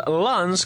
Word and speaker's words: Lens: Pero sí, están jugando el Sabah contra el Slavia Praga y ahora estamos viendo al Lens,